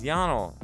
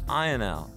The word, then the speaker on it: Ionel: I've been told that the correct pronunciation is Yanal, but I've also heard a lot of folks call him Ionel.